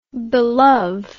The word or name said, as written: belove